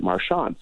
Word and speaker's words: Marchand: My dad actually used to be pronounced Marchant or Marchant. Mm-hmm. And my dad said, I don't like that. We're going to pronounce it Marchand. So that's where it changed, was with my dad.